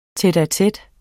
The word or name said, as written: tete-a-tete